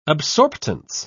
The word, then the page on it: absorptance: British English